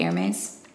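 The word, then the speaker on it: Hermès: Hermes? – Yeah.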